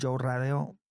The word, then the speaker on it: Joe Radeo: Pues en mis redes sociales, tal cual como se escucha mi nombre, Joe Radeo, J-O-E, se escribe J-O-E, Radeo, R-A-D-E-O.